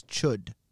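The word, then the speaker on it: Chüd: All right, it's got an umlaut on the U. – Yes. – So actually the pronunciation is Chud.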